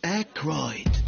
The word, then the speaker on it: Aykroyd: Chevy Chase, Gilda Radner, Dan Ike, wie zum Henker spricht man den aus? – Aykroyd.